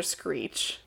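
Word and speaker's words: Sckreech: With a K! Actually, with a C and a K. S-C-K-R-E-E-C-H. – Oh, you make my brain hurt. – The K is silent.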